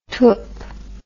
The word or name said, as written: twp